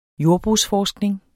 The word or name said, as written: jordbrugsforskning